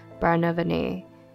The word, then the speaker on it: Barnevernet: So it's spelled Barnevernet, but I think it's pronounced Barnevernet.